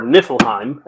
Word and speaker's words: Niflheim: So, uh, there is a kingdom called Lucis, which is defending itself against a encroaching empire of Niflheim, or Niflheim, as it's said in the, uh, the movie. – Spoilers, the correct pronunciation has the slightly longer I.